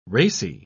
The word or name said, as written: racy